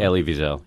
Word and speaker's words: Elie Wiesel: and Oprah was being honored by the Elie, how do you say it? Wiesel? – Elie Wiesel. – Wiesel, yeah.